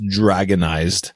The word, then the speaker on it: Dr460nized: So I downloaded the, Garuda KDE, DR460NIZED, which would be pronounced dragonized,